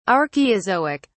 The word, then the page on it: archeozoic: British English